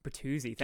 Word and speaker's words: Bertuzzi: I don't know how to say his Italian origin last Bertuzzi. – Name. Bertuzzi. Thank you.